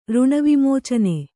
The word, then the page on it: ಋಣವಿಮೋಚನೆ: Kannada